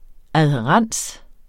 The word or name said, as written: adhærens